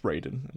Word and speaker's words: Raiden: Every time I see it's pronounced Raiden in Mortal Kombat, but I always think of you now, Mark.